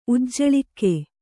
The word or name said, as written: ಉಜ್ಜಳಿಕೆ